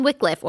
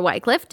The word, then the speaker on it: Wycliffe: John Wycliffe, or Wycliffe, depending on how you want to pronounce it, he lived 200 years before Luther.